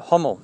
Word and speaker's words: Hummel: Some pronounce it Hummel. It's H-U-M-M-E-L.